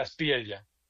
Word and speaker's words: Espriella: Abelardo de la Espriella. – Ah, con doble ella. L. Con ella, ok. Exacto.